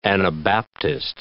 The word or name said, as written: Anabaptist